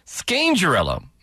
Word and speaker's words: Scangarello: Scangarello, right? That's how you say it. Right, there's no R, sorry. Scangarello, my apologies.